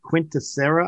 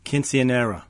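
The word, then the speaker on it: Quinceañera: Quinceanera.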